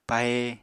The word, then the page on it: pahe: Apatani